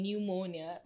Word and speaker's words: pneumonia: The the the the the P in pneumonia is completely redundant.